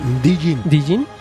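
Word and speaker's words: djinn: Un demonio Dwayne. ¿Cómo se pronuncia este? – ¿Cuál? – Un demonio Dwin. – Dijin.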